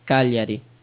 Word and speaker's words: Cagliari: Cagliari. – Cagliari? – Cagliari. – Okay. – Yeah. The G is silent.